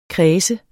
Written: kræs

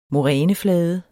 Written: moræneflade

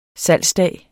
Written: salgsdag

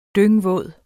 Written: dyngvåd

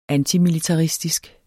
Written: antimilitaristisk

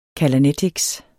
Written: callanetics

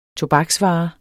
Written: tobaksvare

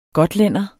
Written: gotlænder

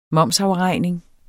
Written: momsafregning

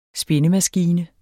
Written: spindemaskine

